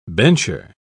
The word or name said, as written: bencher